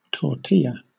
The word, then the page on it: tortilla: British English